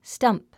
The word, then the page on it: stump: stʌ́mp; スタンプ